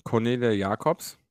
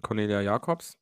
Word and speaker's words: Cornelia Jacobs: Cornelia Jacobs. Nicht Jacobson, sondern Cornelia Jacobs.